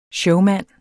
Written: showmand

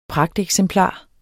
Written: pragteksemplar

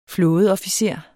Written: flådeofficer

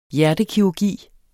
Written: hjertekirurgi

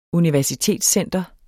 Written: universitetscenter

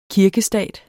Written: kirkestat

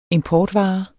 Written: importvare

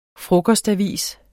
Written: frokostavis

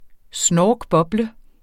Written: snorkboble

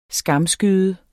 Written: skamskyde

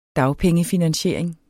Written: dagpengefinansiering

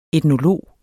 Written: etnolog

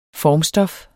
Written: FORMSTOFFET

